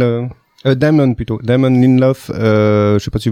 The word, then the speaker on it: Lindelof: avec Damon Lindelof je sais pas comment ça se prononce Lindelof c'est bien, oui.